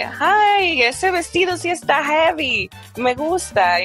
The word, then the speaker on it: jevi: Heavy. – And it's not heavy as in heavy in English. – Okay. How would you spell that? – Yeah. I would spell it J E V I.